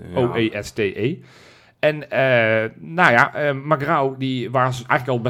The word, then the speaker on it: Magrão: Magrão. – Nou, perfect. – Echt waar? – Ja, Gerson Magrão, want zo spreek je het uit.